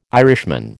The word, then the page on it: Irishman: American English